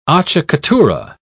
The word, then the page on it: acciaccatura: British English